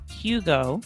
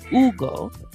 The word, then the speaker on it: Hugo: So that name, I think, really highlights a lot of mistakes that that English speakers make, because you have first the H, right, which is pronounced, which is not pronounced. Then you have the letter U, which sometimes, like I said, we would say Hugo.